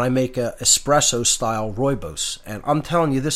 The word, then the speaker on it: rooibos: Rooibos, it's spelled R-O-O-I-B-O-S, pronounced rooibos. Looks like Rue-ebos, but it's pronounced rooibos, and it's just, it's fantastic.